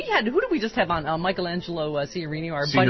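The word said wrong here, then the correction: Signorile: Signorelli. Sorry. You know, Urza Klein is the one that told me to mispronounce that.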